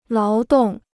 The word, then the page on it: 劳动: láo dòng